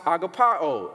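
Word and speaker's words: agapao (ἀγαπάω): Now, this word love here in the Greek form is pronounced agapao. It's where you get agape from, which connotes the fact that Jesus is actively loving them.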